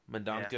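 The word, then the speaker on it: Mendonça: Uh, let's talk about Trinity number seven, a fill-in issue written by Cullen Bunn, illustrated by Clay Mann and Miguel Mendoca. Mendoca. Mendoca. – Mendonca. Yeah. I'm sorry. – Mendoza. Mendoza. Uh, maybe. The accent on the C.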